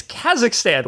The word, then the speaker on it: Kazakhstan: It is headed to Kazakhstan, which is the Florida and Texas of Central Asia. – It's Kazakhstan. Why do you add an extra syllable every time? – My wife pronounced it like that.